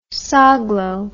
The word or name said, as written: Soglow